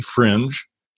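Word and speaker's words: Frinj: Am I saying it right? Because it's F-R-I-N-J. It sounds very Californian. Do you mean it to be Fringe? – Yeah. Hi. Fringe Coffee is just it is how it's spelled.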